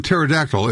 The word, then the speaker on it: pterodactyl: Just remember, the P in pterodactyl is silent.